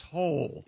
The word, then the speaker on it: Hole: But it's pronounced Hole. – Yeah. – Hole? – Detective Hole. A. Hole. Yeah. – It's spelled H-O-L-E, Detective Hole.